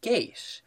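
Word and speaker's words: geis: something called a geish, which comes from medieval Irish epic literature and folklore.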